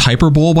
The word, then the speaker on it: hyperbole: But I think it's pronounced hyperbole, by the way.